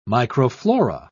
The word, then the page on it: microflora: British English